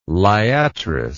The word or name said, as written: liatris